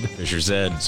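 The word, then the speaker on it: Fischer-Z: would pronounce the name of Fisher Z as Fisher Z, whereas in the U.K., where they're from, Z would be Zed. – Fisher Zed. So it'd be Fisher Zed, except their specific dialect, they don't pronounce the R. So it's Fish's Head.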